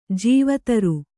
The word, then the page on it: ಜೀವತರು: jīva taru